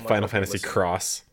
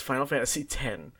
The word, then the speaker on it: Final Fantasy X: There are people who are saying like Final Fantasy X, like still to this day, and I'm Final Fantasy Cross. – It's Final Fantasy X.